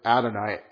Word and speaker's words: Adonai: And now, Adonai is, this is the actual word Adonai. A-D-O-N-A-I, Adonai.